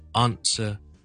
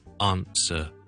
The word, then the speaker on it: answer: 有听众问萧叔叔一个字的英文发音，他说 A-N-S-W-E-R 这个字，答案。咁佢听到英国人讲嘅时候，觉得啊，点解个发音咁特别嘅咧？听一听个发音先，系 answer, answer, answer. OK. 它的发音其实是跟美式英文是争好远的。